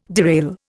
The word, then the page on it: derail: Indian